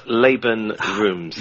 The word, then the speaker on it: Laban Roomes: Uh, it's actually pronounced Laban Roomes.